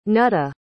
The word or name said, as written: nuttah